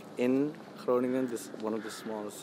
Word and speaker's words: Groningen: So you're born in, how do you say it, Groningen? – Groningen, yes.